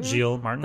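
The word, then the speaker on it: Giles Martin: Gilles Martin.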